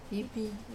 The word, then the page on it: pipi: wamesa